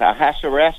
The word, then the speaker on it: Ahasuerus: Was it Ahasuerus? Something like that. That's how you pronounce it.